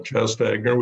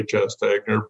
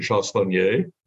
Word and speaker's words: Chastagner: It's a French name. In our family, we call it Chastagner. We say Chastagner, but I've been corrected when I've been in France. They pronounce it Chastagner.